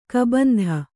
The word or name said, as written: ಕಬಂಧ